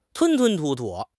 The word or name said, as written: 吞吞吐吐